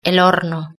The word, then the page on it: el horno: European Spanish